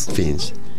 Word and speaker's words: Fiennes: Acordémonos que hay una película en el año 2018 dirigida por Ralph, usted lo pronuncia mejor, por Ralph Fiennes, creo que se llama. – Fiennes.